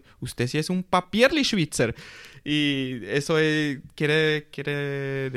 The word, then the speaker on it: Papierli: Papierli A ver si lo digo bien. Papierli así, así, sí, así es.